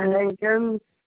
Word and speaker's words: Hankins: Paula Hankins.